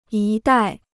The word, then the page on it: 一带: yī dài